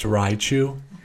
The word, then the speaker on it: Raichu: But may I say, Raichu is way better to look at. – I think it's pronounced Raichu.